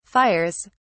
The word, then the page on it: fires: British English